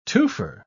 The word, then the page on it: twofer: British English